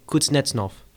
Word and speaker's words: Kuznetsov: All right, how do you say his name one more time? – Kuznetsov. You're too much tongue. Kuznetsov.